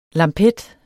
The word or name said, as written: lampet